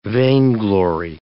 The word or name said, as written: vainglory